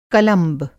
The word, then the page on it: कलंब: Hindi